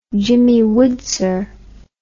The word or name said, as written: Jimmy Woodser